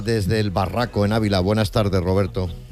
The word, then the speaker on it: El Barraco: Desde el Barraco, Ávila. – El Barraco. Barraco, Barraco. Vale, vale, vale. – Lo he dicho bien. – Lo ha dicho bien.